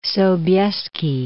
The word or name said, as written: John III Sobieski